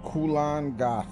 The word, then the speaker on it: Kulan Goth: Kulan Goth. – Okay, so he says, my first student has arrived from the sky. I am Kulan Goth.